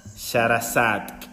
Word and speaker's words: Shahrazad: Es una S-H-A-H. Quítale esa H porque es muda, entonces soy Sharazad. – Sharazad.